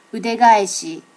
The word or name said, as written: udegaeshi